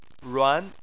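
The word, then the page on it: ruan: tone 2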